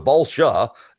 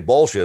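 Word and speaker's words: Balša: But it's Balsa. He goes Balsha, S-H-A, Balsha. It's spelled S-A.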